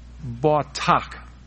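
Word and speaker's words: batak (בָּטַח): Now, the word trust there in Psalms 37 and verse 5, in the Hebrew, Strong's Dictionary pronounces that as batak.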